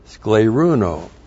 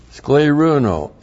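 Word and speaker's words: scleruno (σκληρύνω): And then there's another verb for teaching the scar tissue of the heart, and that is the verb scleruno. Scleruno, S-K-L, long E, R-U-N, long O, scleruno.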